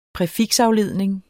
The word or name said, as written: præfiksafledning